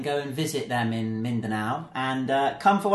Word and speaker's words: Mindanao: M-I-N-D-A-N-A-O. Mindanao.